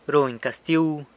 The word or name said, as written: runy castiu